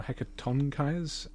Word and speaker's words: Hecatonchires: So let's see here, the Hecatonchires, or Hecatonchires, emphasis on the third syllable, hundred-handed ones...